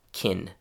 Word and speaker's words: can: The real-life pronunciation of can is more like kin, not can, but kin.